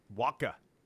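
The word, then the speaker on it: Wacha: Michael, how do you say his last name? – Waka.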